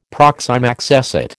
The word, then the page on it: proxime accessit: American English